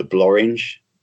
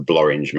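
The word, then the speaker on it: Blorenge: One is called the Blorringe, which is, whoever says nothing rhymes with orange, come to Abergavenny because the Blorringe Mountain is there.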